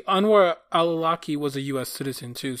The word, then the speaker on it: Anwar al-Awlaki: And even in that same year, in September of 2011, he had another major victory with taking out Anwar al Al-Awlaki, I can't even say his name, Anwar al-Awlaki, basically that was like the second in charge of Al-Qaeda under homeboy Osama bin Laden.